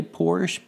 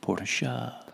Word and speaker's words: Porsche: Most people say Porsche, but the proper pronunciation is Porsche.